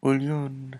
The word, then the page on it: ulyun: Lwaitel